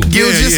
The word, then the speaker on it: Gilgeous: Did I say it right? – Gilgeous. – Now I'm telling you, man, I'm getting better. – Gilgeous. Yeah, yeah. But you was right there.